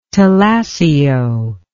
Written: talassio

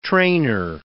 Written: trainer

